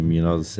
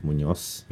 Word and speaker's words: Munoz: I call you Alex Labart, but on the way down, I'm like, Nick, how do you pronounce Munoz? – My Sydney mates call me Munoz, and I guess the Spanish term is Munoz. – Yeah. – But being Aussie, it's Munoz. And then you have Labart, which is my mother's last name.